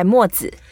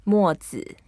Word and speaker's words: Mo Zi: That was done by Mo Zi. – Ah, that's how you pronounce it. – Mo Zi. – Mo Zi.